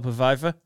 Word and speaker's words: Pfeiffer: The very well-known film with Michelle Pfeiffer.